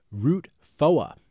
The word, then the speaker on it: Route 4: Yeah, and in this story, Route 4 is actually pronounced Route Foa.